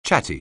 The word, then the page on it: chatty: anglais